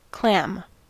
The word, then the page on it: clam: American English